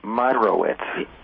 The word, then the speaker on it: Meyerowitz: Just like you said it, Meyerowitz. – Meyerowitz, well, yeah. – There's an extra syllable in there, but, you know, I have to give that one to my ancestors.